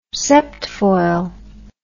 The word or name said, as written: septfoil